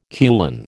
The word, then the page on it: kulan: American English